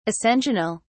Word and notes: ascensional: British English

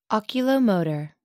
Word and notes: oculomotor: American English

